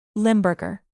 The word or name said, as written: limburger